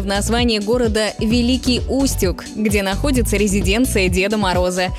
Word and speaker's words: Великий Устюг: Но запомните, что правильно говорить «Великий Устюг» с ударением на «у».